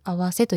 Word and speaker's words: 袷: 衣が合わさって合わせと読みます。